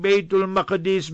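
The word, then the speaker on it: Bayt al-Maqdis: Give us the fatwa and the verdict and decree that regarding baytul maqdis, baytul muqaddas, both ways you can pronounce it.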